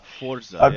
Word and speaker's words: Forza: And I think I might also pick up Forza. Or Forza. – Really? – I forgot. We have to ask our resident Italian how it's supposed to be pronounced. – Forza.